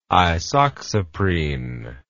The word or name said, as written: isoxsuprine